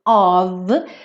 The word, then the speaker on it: of: Mas quem acompanha a série Aprenda Inglês com Música desde o começo já ouviu 500 vezes que of é sempre pronunciado como um V, tá?